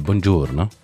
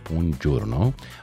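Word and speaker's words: buongiorno: نريد النطق بهالطريقة، بونجورنو. طبعا هذه الكلمة، يعني الكلمة الإيطالية والنطق الصوتي طبعا بونجورنو، وتستخدم في إيطاليا من الصباح وحتى فترة ما بعد الظهر.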